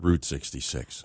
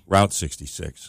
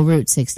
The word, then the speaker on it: Route 66: I think you have to pronounce it Route 66. – What did I say? – Route 66. – Oh, Route 66.